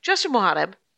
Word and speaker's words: Justin Mohareb: Justin Mohareb. – Mohareb. Yes. – Okay. Yes. Say it fast. Say your name. – Mohareb. – Okay. Justin Mohareb.